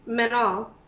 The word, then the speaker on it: Manon: It's weird because it's spelled Manon, but when they say it in the movie, they say Menon. They leave that N off.